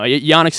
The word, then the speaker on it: Jannik: Yannik Sinner.